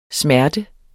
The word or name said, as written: smerte